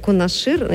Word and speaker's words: Кунашир: Тем временем, вот говоря о медведях, в России на острове Кунашир, я думаю, ударение как, не знаю. – Правильно, правильно.